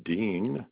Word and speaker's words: Duin: The last name is Dean, but it's spelled D-U-I-N.